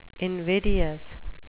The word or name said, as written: invidious